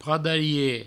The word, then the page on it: pradarie: provençal